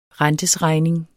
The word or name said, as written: rentesregning